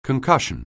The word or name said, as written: concussion